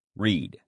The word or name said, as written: read